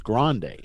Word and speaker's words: Grande: Grand with an E on the end is pronounced Grande.